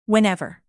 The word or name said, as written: whenever